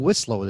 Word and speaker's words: Wcislo: But at the same time, I heard somebody else saying Wislow the day before. And my last name is weird. It's spelled W-C-I-S-L-O.